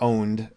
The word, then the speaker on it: Pwned: We'll have a link in the show notes to a site called Have I Been Pwned, or Owned. You could pronounce it that way.